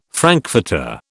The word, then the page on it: frankfurter: British English